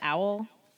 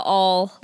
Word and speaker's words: awl: From the owl? Is that how you pronounce that? – No, the all, like the tool. A-W-L.